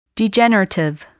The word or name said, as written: degenerative